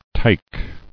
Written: Tike

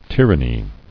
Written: Tyranny

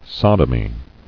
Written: Sodomy

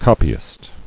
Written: Copyist

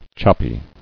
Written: Choppy

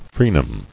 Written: Frenums

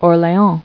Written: Orleans

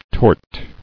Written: Tortious

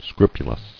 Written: Scrupulosity

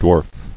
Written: Dwarfs

